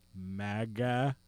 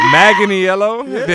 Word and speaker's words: Manganiello: Alcide, the actor known as Joe Manganiello. – There you go. – Manganiello. – Yeah, Manganiello.